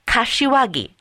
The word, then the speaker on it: Kashiwagi: It's Kashiwagi, not Kawasaki. K is in Korea, A as in America, then Singapore, Hong Kong, India, Washington, America, Greece, and India.